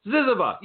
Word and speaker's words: ZYZZYVA: Now his work has appeared in more than 100 literary journals worldwide, including what I think is pronounced ZYZZYVA. I've seen that name for all these years. I've never tried to pronounce it. ZYZZYVA, yes.